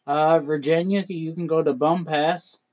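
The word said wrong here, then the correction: Bumpass: Oh, wait, it's pronounced Bumpus. – Oh. – Sorry. They corrected me.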